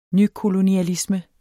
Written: nykolonialisme

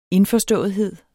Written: indforståethed

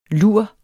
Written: lur

